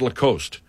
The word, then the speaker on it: Lacoste: Lacoste.